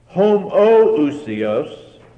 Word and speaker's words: homoousios: Homoousios means of the same nature or the same substance, while homoiousios, the only difference is an O or an OI, is that middle syllable.